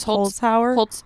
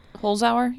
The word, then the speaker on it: Holzhauer: Someone sent us a tweet about the Jeopardy guy, James Holzhauer. Is that how you say it? – James Holzhauer. Holzhauer. Yeah.